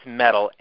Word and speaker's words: Mettle: Sure, yeah, it's Metal, M-E-T-T-L-E.